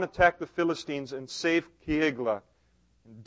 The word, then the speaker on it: Keilah: I just want you to know that I looked up in the pronunciation book in my office, this city, and the pronunciation is Keilah.